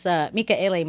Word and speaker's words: Mika'ele: Now, for people out there that want to find his Facebook page, his name is spelled MIKA_ELE. And I think on your Facebook page, your profile name is Mika'ele Michael Armstrong, I think.